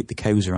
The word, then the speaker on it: Xhosa: The largest, if I recall, are the Zulu and the Xhosa, which is spelled X-H-O-S-A. Now, don't worry about this, folks. I had trouble pronouncing this, and I thought, how do you pronounce the X? But that's what they're called, the Xhosa.